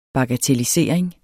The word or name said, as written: bagatellisering